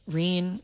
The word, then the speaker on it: Reen: But Reen, it fits. R-E-E-N. You know, I started thinking about like if it should be R-E-N-E.